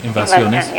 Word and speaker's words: invasiones: my Spanish is horrible, but invasions, is that how you pronounce it? – Oh, yeah. The invasiones. – Yeah. – Invasiones, is that okay?